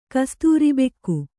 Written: ಕಸ್ತೂರಿಬೆಕ್ಕು

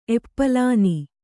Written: ಎಪ್ಪಲಾನಿ

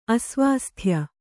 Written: ಅಸ್ವಾಸ್ಥ್ಯ

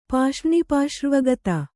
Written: ಪಾಷ್ರ್ಣಿಪಾಶ್ರ್ವಗತ

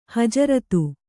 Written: ಹಜರತು

